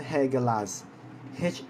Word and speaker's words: Hagalaz: Hagel，Hagel，OK，Hagel 这个的话呢是错的，OK，它真正的 pronounce 的话呢是 Hagelas，H A G A L A Z，Hagelas，不是黑根达扎，Hagelas，OK，H A G A L A Z，什么叫温柔 custom？温柔，OK，这个的话呢是 Hagelas，OK 啊。